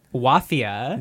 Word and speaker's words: Wafia: Wafia. – Which rhymes with mafia. – Rhymes with mafia, spelled like mafia, but with a W.